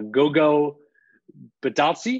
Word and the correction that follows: Goga Bitadze: Goga Bitadze verbal altercation with assistant coach Greg Foster.